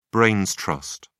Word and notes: brains trust: British English

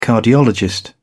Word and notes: cardiologist: British English

